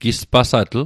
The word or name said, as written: gispasatl